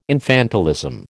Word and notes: infantilism: American English

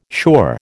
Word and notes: sure: American English

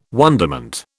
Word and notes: wonderment: British English

